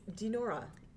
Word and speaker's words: Dinorah: It's Dinorah.